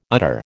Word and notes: udder: American English

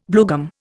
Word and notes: bluegum: Indian

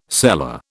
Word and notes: seller: British English

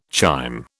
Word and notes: chyme: British English

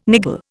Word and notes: niggle: Indian

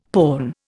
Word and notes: porn: Indian

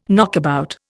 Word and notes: knockabout: Indian

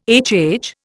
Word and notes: HH: Indian

